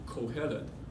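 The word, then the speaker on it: Kohelet (קהלת): 传道者的发音是 Kohelet，希伯来文的发音。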